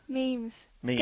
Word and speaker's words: meme: Memes. – Memes. – Dad, good job. Last time you said memes, you said memes. – Okay, well, it's M-E-M-E. That's pronounced it like it looks.